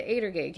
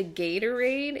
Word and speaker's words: Gatorade: And she, they looked at her and said, you mean the Gatorade? And she's like, what are you talking about? She's like, it's spelled Gator. She's like, yeah, but you say Adergade.